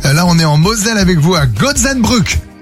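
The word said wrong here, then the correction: Goetzenbruck: À Gutzenbruck, oui, c'est ça. – Ça va? – Non, tu prononces pas bien, il t'a repris, Arnaud. – C'est pas mal. – C'était bien. – Gutzenbruck.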